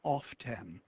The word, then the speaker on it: often: And at that point, she said, it's pronounced often. – Yeah. – So I think there was a sense. – Oh, so my often wasn't street language. It was conveyed to me at PS 205. – And I was at PS 199. – Yeah. – So you had six on me on that one. – Interesting. Well, what do you say now? – Well, I think that I think the belief is that the standard pronunciation should be often.